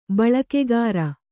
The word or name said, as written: ಬಳಕೆಗಾರ